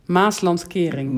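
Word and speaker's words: Maeslantkering: Speaking of the Maasland, I want to say this correctly. Maasland. – Maaslandkering. – Maaslandkering, which is Maasland barrier.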